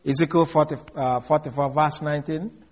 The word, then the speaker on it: Ezekiel: In case you don't know what I pronounce, E-Z-E-K-I-E-L, Ezekiel.